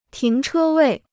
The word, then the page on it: 停车位: tíng chē wèi